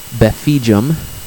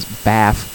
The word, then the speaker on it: Befijum: However, online I go by the moniker Befijum. It is very often much easier to pronounce just the first four letters as Baf, and most people do, so I wouldn't fault anyone for doing so.